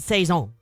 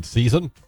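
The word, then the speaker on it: sazón: It's called Saison. – You mean season? – Yeah, I think, no. It's really S-A-Z-O-N, the accent mark over the Z.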